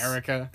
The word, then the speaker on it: Erica: And it's not pronounced Eureka Quell. – It's not. – For the longest time, I thought it was Eureka Quell, which I thought it would have been cool. Which is how it's... just Erica.